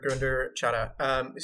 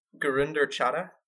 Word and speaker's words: Gurinder Chadha: Okay, so this director, Gurinder Chadha. So we've talked about... – Say that again. You pronounced that beautifully. Say that again. – Gurinder Chadha? – Yeah.